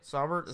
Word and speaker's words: Saubert: In the fifth round, they added another tight end, Eric Saubert from Drake. – It's not that hard to say. – Saubert, is that right? – Yeah, Saubert's right.